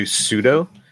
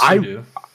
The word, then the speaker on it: sudo: And sometime later, I go to do sudo. And I'm like, crap, I don't know. My password's not working. What the hell's going on? – It's pronounced sudo. – Whatever. – Sudo's right. You're right.